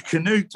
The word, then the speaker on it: Canute: Do you say Canute or Canut? – Canute, apparently, yes, Cnut.